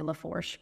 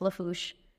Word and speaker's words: Lafourche: if you're looking at it, you want to say Lafourche because that's what it looks that's like. What it looks like. It, they're, the Cajun pronunciations of things are just kind of all over the place. So yeah, it's just Lafourche.